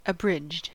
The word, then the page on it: abridged: American English